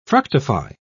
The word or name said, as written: fructify